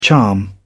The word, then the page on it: charm: British English